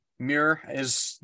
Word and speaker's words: Muir: Because we have Gideon the Knight by Tamsin Muir. How would you say that? – Muir is, that's how I would say it. I had a teacher named Muir back in the day.